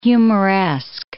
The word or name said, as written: humoresque